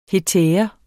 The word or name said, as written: hetære